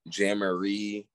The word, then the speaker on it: Jamaree: Um, people can't pronounce my first name. Um, so what did they say? – Jammaree. Yo, I promise you. Um, they call me Jumanji.